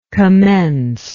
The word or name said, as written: commencer